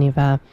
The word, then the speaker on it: Niamh: Her name is Niva Reagan, spelled N-I-A-M-H. It's actually pronounced Niva. I did look it up because I was unsure, and it is an old spelling.